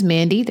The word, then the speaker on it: Mandii: My name is Mandii, that's spelled M A N D I I.